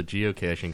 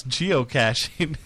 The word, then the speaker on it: geocaching: I like that you can't pronounce geocaching. Every time you've gone to say it, you've said something else. – Geocaching? – Geocaching. – It looks like cash.